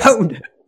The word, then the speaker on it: pwned: We pwned them? – Yeah. Yes, we pwned. Yeah. I actually had to ask how it's pronounced, because I like the word pond, because it sounds like, you know, like in chess, you just, you know, they're a noob, have no power.